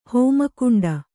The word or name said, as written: ಹೋಮಕುಂಡ